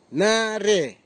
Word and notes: nare: Nafe; Tanna

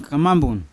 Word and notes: kamambun: Yawa

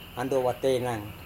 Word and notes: andowa-teinang: Serewen

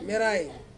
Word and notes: merai: Ansus

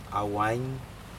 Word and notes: awaiN: Aneityum

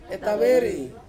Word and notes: taweri: Ansus

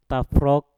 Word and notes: tafrok: mpur

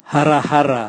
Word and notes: harahara: Raga

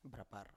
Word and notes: brapar: Biak

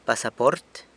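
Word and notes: pasaport: San Lucas Quiaviní Zapotec

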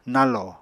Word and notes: nañlò: Apatani

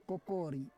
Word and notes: kokori: Roon

